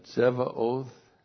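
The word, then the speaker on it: Tsebaoth: So we have the Tetragrammaton, Jehovah, and then Sevaoth, T-S-E-B, Aleph, A, long O-T-H, Jehovah Sevaoth.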